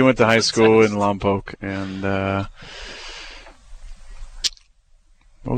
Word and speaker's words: Lompoc: I read that your dad is from Lompoc, California. – Lompoc? – Is that true? I'm actually asking this to see if Jason will pronounce Lompoc correctly. – Yeah, you missed. You messed it up.